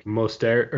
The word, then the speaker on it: Mostert: It's like Mostert, or it's like, sounds like Mozart to an extent, but it's a little different.